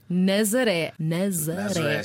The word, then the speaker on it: Nazaré: Nazaré.